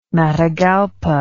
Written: Matagalpa

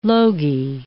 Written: logy

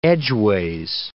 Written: edgeways

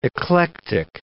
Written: eclectic

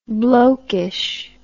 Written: blokeishness

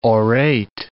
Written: orate

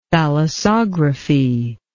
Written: thalassography

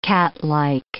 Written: catlike

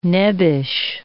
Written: nebbish

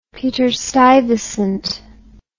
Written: peter stuyvesant

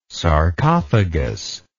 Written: sarcophagous

